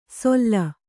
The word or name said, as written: ಸೊಲ್ಲ